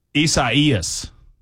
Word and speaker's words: Isaias: It is Isaias. Is what it is. It's Isaias. – Do you think it's like Spanish. – Yeah, Spanish. – It's Isaias. It should be Isaias, but it's Isaias.